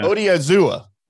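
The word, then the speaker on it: Odighizuwa: Odiazua.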